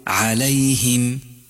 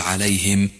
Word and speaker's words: عليهم: الملاحظة الرابعة، انتبه إلى كسر الهاء من قولك غير المغضوب عليهم، لا تقل عليهم، الصواب أن تقول عليهم، أي الفظ كسرة الهاء بشكل صحيح ودقيق، صراط الذين أنعمت عليهم، ولا تقل عليهم فإنه خطأ مشهور أيضا.